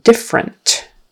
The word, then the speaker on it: different: And let me see here, the word different, different. Short I, different, different, different, different with the accent on the first syllable. Potential, potential, that ends in a T-I-A-L, and that T-I is a sh, S-H sound.